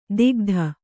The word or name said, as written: ದಿಗ್ಧ